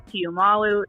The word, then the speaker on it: Tiumalu: You pronounced it just how it's spelled, Tiumalu. That's the way it is.